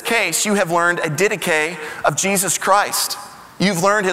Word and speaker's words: Didache: And it looks like the word didache, but it's actually pronounced didache.